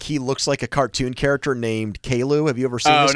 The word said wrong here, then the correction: Caillou: Oh, no, Caillou is how you say the name. – Caillou.